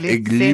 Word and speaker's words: gli: Vio que se pronuncia G-L-I, gli bambini, se pronuncia gli bambini.